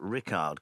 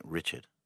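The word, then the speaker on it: Rikard: OK, in 10th spot is, it's spelt out Rickard Karlberg, but I must tell you, having spoken to him in Sweden, I said, do you want Rickard or Richard? He said, I like Richard.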